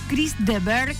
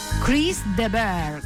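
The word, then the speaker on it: Chris de Burgh: ¿En serio Chris de Berg es rosarino? O sea, claro, Chris de Burg se escribe. Yo pensé que era canadiense. Chris de Berg.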